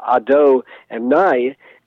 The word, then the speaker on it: Adonai: And that first terminology, which is uh, actually we pronounce it very differently than it's spelled. It's spelled with the Hebrew letters Yud, He, Vav, and then He, uh, but we pronounce it not related at all to the letters of Ado and Nai.